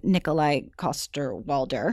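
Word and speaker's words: Nikolaj Coster-Waldau: Also, there was an interesting interview with Nikolai Coster-Waldau.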